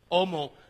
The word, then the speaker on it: homo: It's a silent H, homo, like hombre.